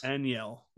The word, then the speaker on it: Enyel: And then we brought back in the swap for Scott Barlow, Enlio Del Santos. – Enlio De Los Santos. – Enyel. There you go. That's how you say it.